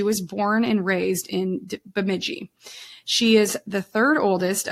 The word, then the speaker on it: Bemidji: So this week we're actually going to be in Minnesota, and we're in Bemidji, and I did listen to the pronunciation several times to make sure I said that right. So Bemidji, we are going to be talking about the disappearance of Nevaeh Kingbird.